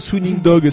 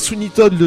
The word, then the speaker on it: Sweeney Todd: Mais oui, toi tu disais Sweeney Dog, c'était marrant. Ouais. c'est Sweeney Todd, le diabolique barbier de Fleet Street.